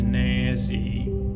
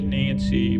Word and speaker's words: Nazzy: and this octopus's name was Nazzy, not Nancy, Nazzy. Can't you say it like Nancy, but it's not Nancy, it's Nazzy the octopus.